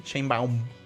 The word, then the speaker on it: Sheinbaum: Sheinbaum.